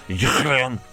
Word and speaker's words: Julien: Here's French football expert Julien Laurent, or to pronounce his name correctly, Julien, explaining Kylian Mbappé's power at PSG.